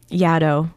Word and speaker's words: Yaddo: Like when you say you've been to Yaddo, that rhymes with shadow, and thank you for actually telling me that.